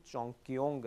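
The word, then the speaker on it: چونگکیونگ (重庆): تیسرا ملک ہے جہاں پر یہ کام کیا جا رہا ہے, سب سے پہلے چین, چونگکیونگ اگر ہاں بالکل یہی تلفظ ہے,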